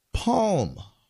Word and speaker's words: Psalm: Palm West. It's spelled P-S-A-L-M.